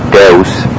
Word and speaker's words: deus: For instance, the word tua, T-U-A, it's not twa, it's tua. The word for God, deus, D-E-U-S, it's not deus, but deus.